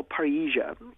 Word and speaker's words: parrhesia: So I think that what we need is what I call parhesia, and it's spelled P-A-R-R-H-E-S-I-A.